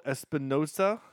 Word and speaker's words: Espinosa: So this movie, yeah, directed by Daniel Espinosa. I probably not pronouncing it the way he would want it. – No, that's good.